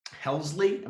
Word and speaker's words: Helsley: That is Ryan Hesley, not to be confused with Ryan Pressly, who is 116 on the list. – Helsley? – Am I saying his name wrong? – I think there's another L.